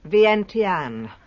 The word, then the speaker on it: Vientiane: How do you pronounce it? – Vientiane, capital of Laos.